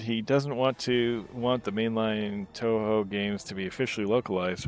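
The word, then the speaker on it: Touhou: It's pronounced Toho. – I was going to say it is Toho.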